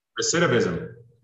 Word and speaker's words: recidivism: How do you say it? – Recidivism.